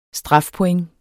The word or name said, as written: strafpoint